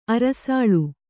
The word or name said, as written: ಅರಸಾಳ್